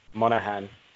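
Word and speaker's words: Monaghan: Cameron Mahognan, I don't know how you say his name, playing Chad. Miley Cyrus. – Monahan playing Chad.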